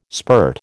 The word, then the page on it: spirt: American English